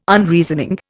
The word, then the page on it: Unreasoning: Indian